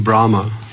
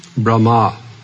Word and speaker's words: Brahma: Because most of the time I'm hearing Brahma. – Потому что большинство вот из случаев, когда я слушаю слово Брама. – Lord Brahma. – Lord Господь Брама. – Wrong pronunciation. – Неверное произношение. – Brahma. You can repeat. – Brahma. – Brahma. Long R at the end.